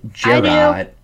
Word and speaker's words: Gerard: It was about a guy named Gerard, pronounced like Jared.